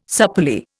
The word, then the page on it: supplely: Indian